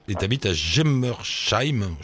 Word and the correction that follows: Germersheim: je prononce ouais, ça. C'est presque ça. – Vas-y, dis-le bien, toi. – Germersheim. – Voilà, Germersheim.